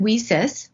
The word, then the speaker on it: WiCyS: We often go by your acronym, WISIS, W-I-C-Y-S. And we do pronounce it WISIS, like we sisters, because that's exactly what we are.